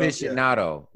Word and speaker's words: Aficionado: How do I pronounce it? Aficionado? – Aficionado? Cigar Aficionado? – Aficionado. Yeah.